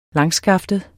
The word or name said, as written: langskaftet